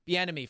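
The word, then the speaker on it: Bieniemy: But if you're, you know, Eric, I always mispronounce his last Bienemy. – Name. Bienemy from Kansas City.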